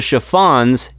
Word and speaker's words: Chiffons: Oh, I'm sorry, I've just been informed it's pronounced the Chiffons. It is not the Chiffins.